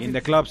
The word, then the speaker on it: In the Club: Dice, ponla de 50 Cent, In the Cloud. – Ubica In the Club, sí, claro. – Ah, In the Club, perdón, sí, es pronunciación sí.